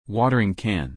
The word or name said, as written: watering can